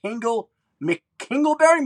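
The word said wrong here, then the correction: Hingle McCringleberry: Am I saying that right? – McKringleberry. – Hingle McKringleberry, yep.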